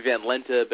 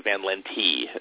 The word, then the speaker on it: Van Lente: If we were in Amsterdam, it would be Van Lente, but it got Ellis Islandized into Van Lente, is how my family's always pronounced it.